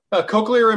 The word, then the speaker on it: cochlear: So like with the, is it cochlear? Am I saying that right? – Cochlear implant. That's correct. Yes.